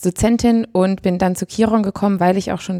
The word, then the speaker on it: Kiron: Ich ähm, bin zurzeit Curriculumskoordinatorin bei Kiron. So spricht man es nämlich aus.